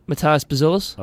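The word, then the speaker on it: Matas Buzelis: Matas Bezelis.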